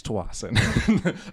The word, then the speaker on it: Tsawwassen: It's Tawasin. I know it's there's a T in there and there's an S in there and there's a couple more S's. – Well, some people people pronounce it with the T silent or the S silent. – What if you do both, like a Russian Tsar? – Tawasin.